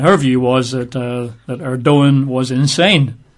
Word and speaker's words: Erdogan: Mr. Erdogan, you write that E-R-D-O-G-A-N, but pronounced Erdogan.